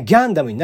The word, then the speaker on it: ガンダム: まあガンダム、まあ発音的にはギャンダムになるんですよね、日本ではガンダムになりますけど、